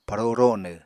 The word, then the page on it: paro ronw: Apatani